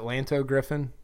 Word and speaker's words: Lanto Griffin: Is it Lanto Griffin? – Yeah, the pride of Virginia Commonwealth.